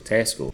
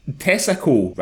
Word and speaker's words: Tesco: Tesco. But Nicole said the hilarity started because Shawnee mispronounces the name of the supermarket giant, saying Tesco rather than Tesco.